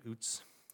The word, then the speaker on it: Uz: Job lives in the land of Uz, looks like Uz, it's pronounced Uz, where he has clearly led a blessed life.